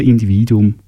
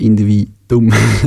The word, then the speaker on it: Individuum: Individuum, das der wieder finden darf. Betonung auf Individuum.